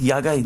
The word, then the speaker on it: Yaga: Yaga, Y-A-G-A?